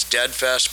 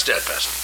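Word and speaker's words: Dedfest: And just for the record, it's Deadfest, but it's spelled D-E-D. So, yeah. That's Deadfest.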